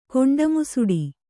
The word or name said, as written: ಕೊಂಡಮುಸುಡಿ